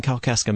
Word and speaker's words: Kalkaska: I want to say welcome to our guest today, Pastor Chad Hoover from St. Paul Lutheran Church in Kalkaska, Michigan.